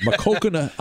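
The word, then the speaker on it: Maquoketa: I'm just going to keep saying that because Chris sent me how to properly pronounce it. So I think I'm saying it right. Makokana, Iowa.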